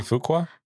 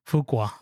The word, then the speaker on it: Fuqua: Like I think the director is Anthony, and I'm gonna butcher his last name, Fukua. – Anthony Fuqua. – Fuqua.